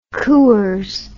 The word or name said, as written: coors